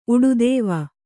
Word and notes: ಉಡುದೇವ: Kannada

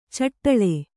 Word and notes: ಛಢಾಳ: Kannada

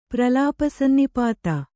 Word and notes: ಪ್ರಲಾಪಸನ್ನಿಪಾತ: pralāpa sannipāta

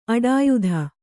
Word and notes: ಅಡಿಯೊತ್ತು: kannada

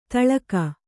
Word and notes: ತೆಲುಗು: telugu

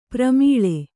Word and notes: ಪ್ರೇಮಲೆ: prēmale

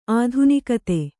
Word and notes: ಆಧುನಿಕತೆ: ādhunikate

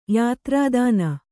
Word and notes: ಯಾತ್ರಾದಾನ: yātrādāna

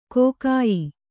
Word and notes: ಕಗ್ಗಾಯಿ: kannada

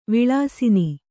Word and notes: ವಿಲಸನ: vilasana

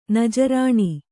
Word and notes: ನಜರಾಣ: Kannada

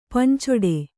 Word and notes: ಪಂಚೊಡೆ: pancoḍe

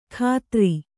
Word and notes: ಖಾತ್ರಿ: kannada

